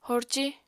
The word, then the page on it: horci: apatani